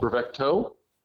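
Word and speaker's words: Bravecto: Is it Brevetco or Brevecto? – Brevecto. T-O. Yeah.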